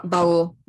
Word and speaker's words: Baul: And I wonder if you wanted to say something about the ball. Is that how you say it? Ball tradition? I don't know if I'm pronouncing the word right. Ball. – Bowl. – Bowl. – Bowl tradition.